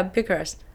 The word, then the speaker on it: Bucharest: Uh, oh yeah, Bucharest.